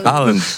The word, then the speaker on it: Haaland: E agora, por conta do jogo do Brasil, depois no domingo, depois da perda, a Luísa Sonza começou a ser comparada com Haaland. – Haaland. Vale qualquer pronúncia. – Mas dizem que... – É que a pronúncia é essa mesmo.